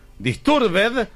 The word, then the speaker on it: disturbed: No hay ninguna palabra en inglés que al final se pronuncie ed. Disturbed se pronuncia disturb.